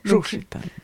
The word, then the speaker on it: Juchitán: alors, excusez-moi pour la prononciation, Juchitán, je ne suis pas sûre. – Juchitán.